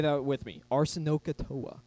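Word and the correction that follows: arsenokoitai: Oh no, pronounce it, arsenokatase.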